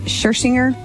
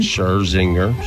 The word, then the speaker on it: Scherzinger: Nicole Scherzinger is 47. – Scherzinger, sure.